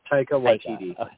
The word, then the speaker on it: Taika Waititi: Yeah, Taika Waititi.